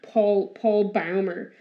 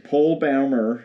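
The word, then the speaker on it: Paul Bäumer: Yeah, Paul Boehmer. – Yeah, Powell Boehmer. – Yeah. – Yeah. – You continue. – You were in the middle of a sentence. I just corrected you on his pronunciation.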